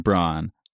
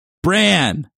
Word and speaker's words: Bran: Number two, one of the people in this podcast has a tendency to pronounce Bran's name Bron. Stop it. Bran, not Bron.